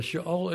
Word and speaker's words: Sheol: Is that also Sheol? I might not be saying it right. – Sheol? – Yeah. – Yeah, Sheol is the Hebrew equivalent.